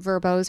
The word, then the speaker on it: VRBO: Like Seattle, I mean, obviously like Port Angeles, you know, unique Airbnbs, because typically if people want their Airbnbs or their um, Verbos or VRBO, some people say it like yeah. – all quite nice. I always I've always said Verbo.